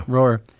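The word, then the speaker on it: Roher: You know, an interesting thing about the film was that the director of the film, Daniel Rohr, is that how you pronounce his last name? Yeah. – Rohr.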